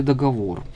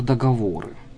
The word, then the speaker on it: договор: Как правильно ставить ударение в слове «договор»? Традиционно все-таки «договор», «договоры». Договора, увы, проникают в нашу речь, но классически все-таки договор, договоры. Не с ударением на первый слог, хотя постепенно первый слог все более и более употребим.